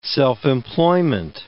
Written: self-employment

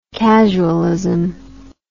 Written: casualist

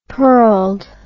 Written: pearled

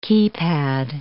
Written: keypad

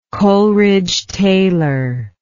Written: Coleridge-Taylor

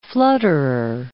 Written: flutterer

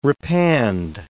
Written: repand

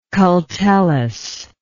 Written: cultellus

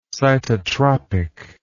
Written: cytotropic

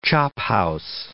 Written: chophouse

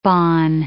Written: bonne

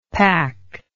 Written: pak

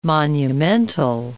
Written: monumental

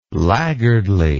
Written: laggardly

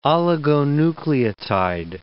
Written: oligonucleotide

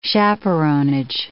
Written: chaperonage

